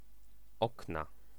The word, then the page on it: okna: /ˈɔkna/